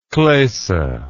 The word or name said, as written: klosse